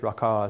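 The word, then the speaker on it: Raca: And whoever says to his brother, Raka, or Raka, if you look it up, it's Raka is how they say it. It's got a little English on it, so to speak.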